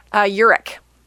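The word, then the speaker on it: Ehrich: It's like E-H-R-I-C. Like, it's, you know... And when I first thought, I was like, okay, I need to check this out. So I YouTubed it to find an interview where he was going to be announced with his full name. And right. It is pronounced Eric.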